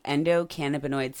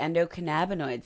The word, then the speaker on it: endocannabinoid: We're going to discuss the ECS, or the endocannabinoid system. You might have also heard it called the endocannabinoid system. Both pronunciations are valid and correct, and honestly, I use both, so use whichever one you're most comfortable with.